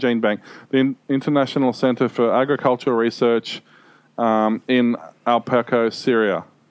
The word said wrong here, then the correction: Aleppo: Aleppo. A-L-E-P-P-O. – A-L-E-P-P-O.